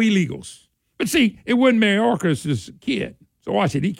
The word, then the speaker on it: Mayorkas: Matter of fact, Mayorkas. Am I saying this right? – Mayorkas. – Okay, Mayorkas.